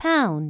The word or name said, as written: HASH